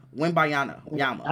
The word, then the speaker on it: Wembanyama: Let me make sure I'm pronouncing this nigga name right, because they try like who? Wembanyama. – I think that's how it legit pronounced it.